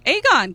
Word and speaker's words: Aegon: Aegon.